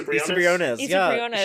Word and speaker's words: Briones: Issa Briones.